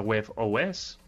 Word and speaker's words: WebOS: G utiliza WebOS, lo digo separado porque si no se dice huevos.